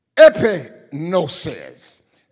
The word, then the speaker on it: epignosis: That's spelled G-N-O-S-K-O-S, genosko. The third word for knowledge is epignosis. That's spelled E-P-I-G-N-O-S-I-S.